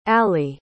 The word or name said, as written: aly